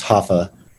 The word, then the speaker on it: Rafa: So I named him Rafael, and they pronounce their R's with like an H. So it'd be Rafael. Yeah. And shortened, it's just Hafa. But they would spell it R-A-F-A. So I did my book H-A-F-A just to make it a little easier for the English audience to pronounce it.